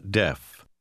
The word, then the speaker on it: deaf: Il numero 8 contiene lo stesso suono corto della E. Lo provi. – I'm not deaf.